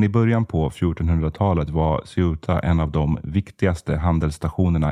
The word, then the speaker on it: Ceuta: Och jag vet att det här kan uttalas på lite olika sätt. Jag har sett Ceuta eller Sebta till exempel, men för konsekvensens skull kör jag på den här varianten.